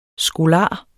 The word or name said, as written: skolar